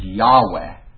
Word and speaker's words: Yahweh: But they've found a more proper, what they believe is the pronunciation, is Yahweh.